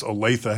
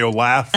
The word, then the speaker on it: Olathe: You've never had somebody take your job away from you and then mispronounce Olathe, have you? – No, I wouldn't know about that. – Okay. They didn't mispronounce it and say Olaf? – I wouldn't remember that.